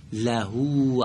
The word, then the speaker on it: له: لحو اگر بگید در عبارت اول اون غلطه، لهو عینینی. خب یک بار دیگه لحو. – نه اگر لحو بگی میشه های جیمی. این های دو چشمه.